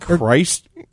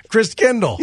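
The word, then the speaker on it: Christkindl: How do we pronounce that? – Chris. – Christ Kindle. – Yes. – It's got a T in it.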